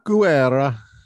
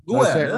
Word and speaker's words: Guerra: Guerra. – Guerra, that's right. And you got to have the hand in the, yes. – Guerra.